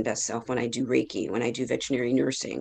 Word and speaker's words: Reiki: Reiki, and the best way to remember how to say it is rays of sun. Okay. Everybody feels amazing when they have rays of sun on their body. – Yes. – So it's Reiki.